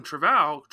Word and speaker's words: Trevorrow: Colin Trevorrow, am I spelling eh, pronouncing that Trevorrow.